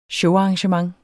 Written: showarrangement

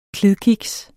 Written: klidkiks

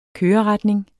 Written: køreretning

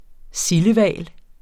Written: sildehval

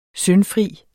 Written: syndfri

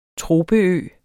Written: tropeø

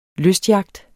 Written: lystyacht

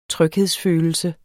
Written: tryghedsfølelse

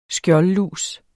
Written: skjoldlus

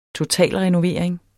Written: totalrenovering

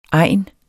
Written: egn

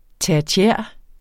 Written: tertiær

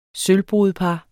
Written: sølvbrudepar

